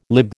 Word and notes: lib: American English

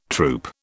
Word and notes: troupe: British English

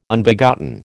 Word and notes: Unbegotten: American English

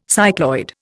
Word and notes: cycloid: Indian

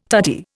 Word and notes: tutti: Indian

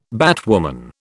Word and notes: batwoman: British English

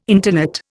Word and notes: intonate: Indian

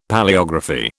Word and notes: Palaeography: British English

